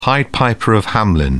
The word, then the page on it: pied piper of hamelin: British English